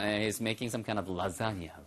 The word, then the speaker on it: lasagna: To your lasagna. Lasagna. L-A-S-A-G-N-A에서 발음은요. 라자니아처럼 발음돼요.